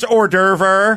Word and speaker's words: hors d'oeuvre: I'll have the same conversation I had with my grandmother when she was explaining to me, you know, it's pronounced hors d'oeuvre. And I went, I'm not going to argue with you, Nana, but everyone says hors d'oeuvre, and I don't want to be made fun of.